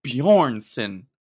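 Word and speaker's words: Björnsson: And your name is Hafthor Bjornsson. I can't make that up. Dude, his name is Hafthor. Oh, so built for it, dude. He basically lifted Mjolnir. And his last name is Bjornsson.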